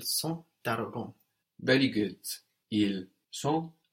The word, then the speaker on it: sont: They are, they are, is il or elle, sont. That's spelled S-O-N-T, sont. S-O-N-T.